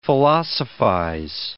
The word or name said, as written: philosophize